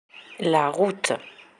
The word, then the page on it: la route: french